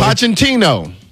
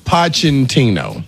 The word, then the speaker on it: Pochettino: Pochettino. – Pochettino? – Pochettino.